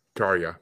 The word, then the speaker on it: Tarja: But she is one of my favorite female metal singers because she is just her, Floor Jansen, Tarja, however you pronounce it. – Tarja, yeah. And it's Finnish name, man.